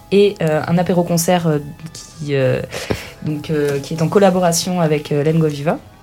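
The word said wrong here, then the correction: Lenga Viva: Tu veux dire Lengo Vivo? – Lengo Vivo, voilà, je suis pas d'ici. – Alors ça s'écrit Lengo Viva, mais en occitan, les A de terminaison se prononcent O.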